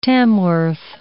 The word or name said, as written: Tamworth